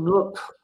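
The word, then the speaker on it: NUC: So in fact, what we're more likely to do is to take 16 of these little Intel Nook, or I'm not sure how to say it. It's supposed to rhyme with truck.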